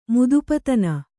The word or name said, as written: ಮುದುಪತನ